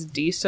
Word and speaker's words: Disa: I'm not sure, but it's Disa Wallander. spelled like Lisa, but with a D.